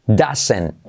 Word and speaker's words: doesn't: This, semiclave TH, y como e, es this, doesn't, la T no se pronuncia.